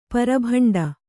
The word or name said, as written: ಪರಭಂಡ